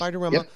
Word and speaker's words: Bartiromo: And this person worked for Maria Bartiromo and Tucker Carlson. Am I saying her name right? – Yes. – Bartiromo.